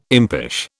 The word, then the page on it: impish: British English